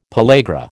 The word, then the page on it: pellagra: American English